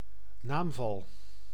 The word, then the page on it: caso: /ˈka.zɔ/